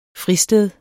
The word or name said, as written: FRISTEDS